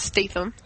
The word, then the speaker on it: Statham: Just to correct you there, Tom, it's pronounced Statham.